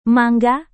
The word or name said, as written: mangga